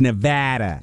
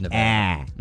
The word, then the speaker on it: Nevada: We were made sure to, you know, pronounce it that way. They told us whenever they played them in basketball, it's Nevada. – It is Nevada.